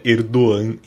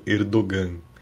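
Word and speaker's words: Erdoğan: Na live do Na Bancada, que a pronúncia correta é Erdogan e não Erdogan.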